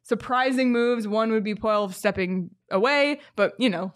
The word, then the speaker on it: Poile: David Poile, that's how you say his name, right? – Yep.